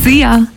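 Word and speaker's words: Szia: See ya. Hallo und tschüss. Könnt ihr euch ganz easy merken. Wird einfach ausgesprochen wie unser Energy Star.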